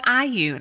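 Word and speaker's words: Allyu: And I just booked an appointment at IU, which I realized I was calling it All U because it's A-L-L-Y-U. But I just called today and she's like, hi, thanks for calling IU. And I said, what? Like, excuse me, how do you pronounce this? So, you know, learn something new.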